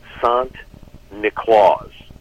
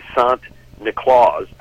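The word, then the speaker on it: Sint Niklaas: So the Dutch pronunciation of St. Nicholas is Sant Niklaus. So if you say Sant Niklaus a couple times, you come up with Santa Claus.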